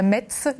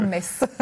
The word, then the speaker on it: Metz: Pas Metz, mais bien Metz.